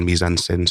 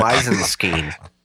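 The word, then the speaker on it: mise-en-scène: It was supposed to be a report on mise-en-scene, so I failed. But it's still learned a lot. – Mise-en-scene. – Mise-en-scene, mise-en-scene, mise-en-scene. Everyone pronounces it different. It's French.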